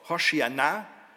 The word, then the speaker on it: Hosianna: Also Hosianna ist ein hebräisches Wort, Hoshiana wird das ausgesprochen. Und Jesus ist ja eigentlich auch ein hebräischer Name, wird ausgesprochen Jeshua.